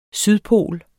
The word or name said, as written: sydpol